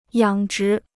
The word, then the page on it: 养殖: yǎng zhí